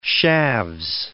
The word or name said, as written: shaves